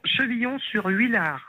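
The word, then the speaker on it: Chevillon-sur-Huillard: Euh, comment vous prononcez exactement le nom de votre ville Chevillon, Chevillon sur Huillard.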